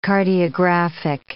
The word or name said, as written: cardiographic